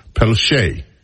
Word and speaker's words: Pelletier: It looks like Pelletier. And that is the way that many people would pronounce it. However, I happen to know that there's a French pronunciation that is also Pelletier. P-E-L-L-E-T-I-E-R.